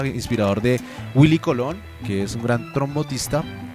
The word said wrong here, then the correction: trombonista: ¿cómo se dice? – Trombonista. – Trombonista, sí, pues, trombonista.